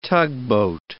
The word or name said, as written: tugboat